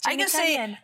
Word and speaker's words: Jeannie Cunnion: Yes, Jeannie Cunnion.